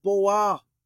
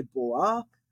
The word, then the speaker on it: בוא: And you can see when I type in there, Boah, some people actually will just say Bo as their pronunciation. They'll leave that Aleph on the end unpronounced. I like pronouncing it. So they'll say Bo. I say Boah.